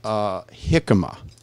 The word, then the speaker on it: jicama: We were talking about fermented jicama, and it's spelled with a J. So it could throw you off. Jicama. – Or jimica.